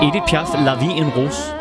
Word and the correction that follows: La Vie en Rose: Som jeg overhovedet ikke udtaler rigtigt. – Nej. – Hvordan skal det siges? – La Vie en Rose.